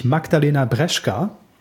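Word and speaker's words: Magdalena Brzeska: Eine echte Olympionikin haben wir auch noch am Start, nämlich Magdalena Breschka. Ich hoffe, dass der Name zumindest so richtig ausgesprochen ist. Ja, hast du komplett richtig ausgesprochen, ja.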